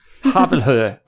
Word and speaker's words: Havelhöhe: And now there's more idiocy coming from the same source, namely from his hospital, the Havelhöhe Community Hospital in Berlin. Do you pronounce it anywhere remotely like that? – Yes. – Havelhöhe.